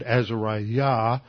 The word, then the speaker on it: Azariah: Now when I said two of those names, Hananiah and Azariah, I'm emphasizing the last syllable. In fact, in Hebrew, the last syllable would have been accented anyway.